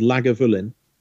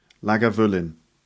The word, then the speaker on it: Lagavulin: My favorite whisky from there is called Lagavulin, which is spelled Laga. – Yeah. – Vul. – Yeah. – In. – Lagavulin.